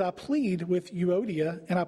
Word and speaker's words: Euodia: And according to the Bible pronunciation guide website that I looked this up on, their names were Euodia and Syntyche, because I had no idea how to say either one of those.